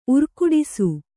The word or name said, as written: ಊರುಗೆಡಿಸು